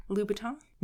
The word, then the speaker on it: Louboutin: Louboutin. – With a B? – Yeah. Louboutin.